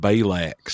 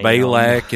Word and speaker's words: Balak: That's supposed to be called Balak, son. – And Balaam. – Balak and Balaam.